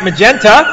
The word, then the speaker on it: magenta: Her magneta flowing... – Some would pronounce that magenta.